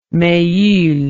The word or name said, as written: mehul